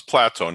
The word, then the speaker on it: Plato: It's Plato, not Plato. Yeah.